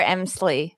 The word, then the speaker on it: Emslie: yes, Claire Emslie.